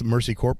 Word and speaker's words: Corps: That's Mercy Corps, basically is how you spell it, Mercy Corps. You throw that P and that S is silent.